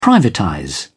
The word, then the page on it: privatize: British English